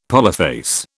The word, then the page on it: polyphase: British English